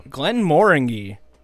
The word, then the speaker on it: Glenmorangie: Glenn Moringy. That is how it's said. – Glenn Moringy? – Glenn Moringy. Moringy. – Moringy. – Moringy or Moringy? Glenn Moringy. It's a hard G.